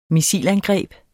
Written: missilangreb

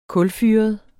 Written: kulfyret

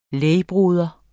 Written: lægbroder

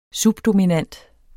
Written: subdominant

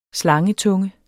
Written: slangetunge

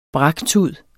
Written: braktud

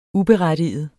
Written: uberettiget

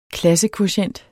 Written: klassekvotient

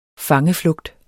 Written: fangeflugt

